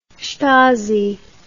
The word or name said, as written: stasi